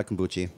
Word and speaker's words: kombucha: Not a kombucha. – No. – Kombucha. – That's the actual correct pronunciation.